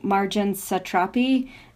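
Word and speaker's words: Marjane Satrapi: Speaking of young Iranian filmmakers, my mother wanted me to ask if you know Marjan Satrapi. I don't know if I'm saying her name right, who made Persepolis. – Yes, you pronounce exactly this right pronunciation.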